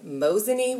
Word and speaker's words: Mosinee: So you are Executive Vice President of Maple Ridge Farms in Mosinee, Wisconsin. Is that how I said it right? – That's right. Yeah, you said it exactly right.